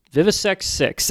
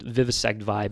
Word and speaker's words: Vivisect VI: Is it pronounced Vivisect Vi or Vivisect Six? – Vivisect Six, actually. Okay. That's something that, you know, when I first heard the record, I just immediately assumed Vivisect Vi,